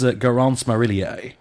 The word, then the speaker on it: Garance Marillier: Her name is Garance Marillier. And good pronunciation. Well done.